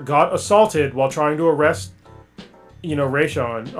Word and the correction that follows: Rayshard: or is that how you say his name? – Rayshard, I think. – Rayshard, yeah.